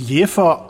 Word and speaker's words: Jever: Also meines Wissens ist, ähm, wird es Jever eigentlich ausgesprochen. Allerdings nennt sich die Biermarke heutzutage in der Werbung selbst Jever, weil es sowieso jeder falsch macht.